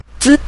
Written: zi5

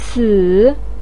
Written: ci3

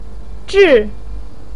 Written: zhi4

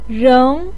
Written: reng2

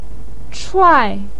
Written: chuai4

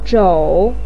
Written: zhou3

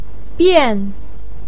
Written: bian4

